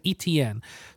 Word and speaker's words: Etienne: Etienne, the second best running back in the Etienne.